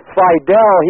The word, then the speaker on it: Fidel: Yeah, man, Fidel. That's how you say it.